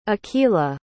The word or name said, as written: akhila